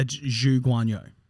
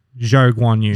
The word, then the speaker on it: Zhou Guanyu: So it's Guan uh, Zhou Guanyu. – Zhou Guanyu.